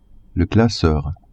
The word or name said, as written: classeur